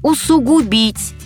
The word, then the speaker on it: усугубить: Строгая норма, сугубая в каком-то смысле, усугубить, усугубят. Запоминалка, усугубить вдвойне губить. А в обиходе допустимо и иное ударение.